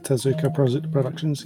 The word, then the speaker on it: Tezuka Productions: The studio behind it, and I apologize for mispronouncing this, is Tezuka Productions. – I would pronounce it Tezuka Productions, yes.